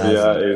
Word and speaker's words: Lazar: So in US they usually go Lazar, like second A is longer. Yeah. In Serbia Lazar. It's like first one is a bit longer.